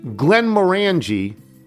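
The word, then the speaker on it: Glenmorangie: Glenn Morangi rhymes with orangey.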